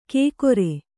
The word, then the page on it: ಕೇಕೊರೆ: kēkore